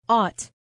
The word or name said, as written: ought